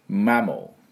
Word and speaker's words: mammal: 海洋生物の哺乳類、哺乳類ってね、英語で何て言うかっていうと mammal、 mammal って言うんですよ。 MAMMAL、まあ、普通に読むとまーまるって読みたくなるような感じなんですけど、実際最後の A はほとんど発音しません。